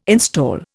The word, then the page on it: install: Indian